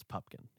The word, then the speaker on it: Pupkin: Butkin. It's not Pumpkin. – It's Pupkin. – Yeah. Pupkin. – But everyone says his name wrong.